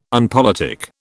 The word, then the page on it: unpolitic: British English